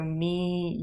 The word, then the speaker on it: mi: So me, you, them, etc. – For me, it's simple. Me. – Yeah, same pronunciation. The spelling is slightly different. M-I. So if you call is chiami, how would you say you call me?